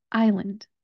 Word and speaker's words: island: This schwa vowel sound can be tricky for students, but just think of it as more of a U than an A. We're going to say Lund, Lund. We're going to now put those together. We're going to take our syllables and make our word. I, Lund. I, Lund. Put it all together. Island, island.